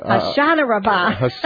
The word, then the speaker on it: Hoshana Rabbah: Anahash, how do you say that? What am I saying? – How about a Hoshana Rabbah? Oh, my goodness. – You do well. Don't worry. You do very well.